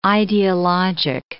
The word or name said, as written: ideologic